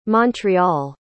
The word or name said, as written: montreal